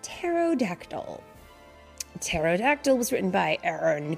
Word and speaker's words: pterodactyl: And I want to say really quick before we get started, this is a tricky word because it has a hidden letter. The P. Mm-hmm. The word pterodactyl is spelled P-T-E-R-O-D-A-C-T-Y-L. P-T-E-R-O-D-A-C-T-Y-L. Pterodactyl. But it has a P in front. You just don't say the word.